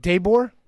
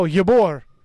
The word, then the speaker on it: Ybor: Daybor? – It's spelled Y-B-O-R. – Oh, Ybor. – Ybor.